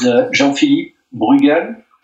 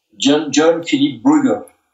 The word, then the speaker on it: Jean-Philippe Bruegel: My name is Jean-Philippe Bruegel. This is a French pronunciation. John-Philippe Bruegel.